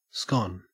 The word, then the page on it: scone: Australian English